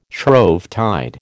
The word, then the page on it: Shrovetide: American English